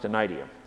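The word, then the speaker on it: anaideia: of the Greek word anaidia, pronounced anaidia.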